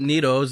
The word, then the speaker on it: Neto: It'd be real neato if you pronounce it neato.